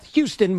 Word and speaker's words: Houston: Now, Houston is spelt Houston, but it's pronounced Houston.